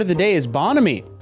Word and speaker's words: bonhomie: Bonhomie. Bonhomie.